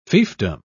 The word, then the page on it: fiefdom: British English